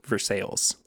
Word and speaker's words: Versailles: even though it's spelled Versailles, Versailles.